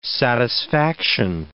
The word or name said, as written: satisfaction